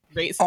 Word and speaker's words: Raisin: And let's just take a moment to appreciate my tiny child self who called it Raven Bran because she couldn't pronounce Raisin.